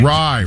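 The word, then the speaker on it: Rai: Rye. – Just Ray, remember, yes. Rye. Rhymes with guy.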